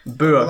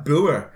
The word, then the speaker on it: Bouer: The others were identified as a 22-year-old Michael Bauer. I don't know how you say it. – When we can't pronounce something, we spell it out. – B-O-U-E-R. So I'm missing... – B-O-U-E-R. – Bauer. – Like Bauer.